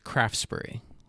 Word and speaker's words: Craftsbury: Coming to you from northern Vermont, Craftsbury, which is known as Craftsbury. The T is silent, just like in Vermont.